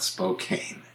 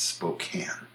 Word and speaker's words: Spokane: It's not Spokane, although it looks like it. It's Spokane.